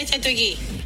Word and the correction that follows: Chateauguay: Bueno, pues saludos hasta Kanawaki, Chateauguay, que así se pronuncia. Pues, Ahora sí lo dijimos bien. Chateauguay.